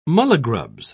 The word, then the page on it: mulligrubs: British English